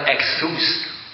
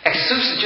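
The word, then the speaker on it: exus (ΙΧΘΥΣ): 其实不是这样子念的啊，它怎么念的？它叫 exus， exus 就是鱼的意思。这个鱼呢，它是怎么样？它是这个耶稣基督神的儿子救赎主，他的希腊文的前面几个字配在一起，刚好就是这个 exus。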